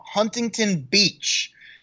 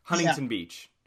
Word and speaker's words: Huntington: from Huntington Beach. Michelle Let me stop you. I love when people actually pronounce the T. It, like, here in California, it's Huntington Beach.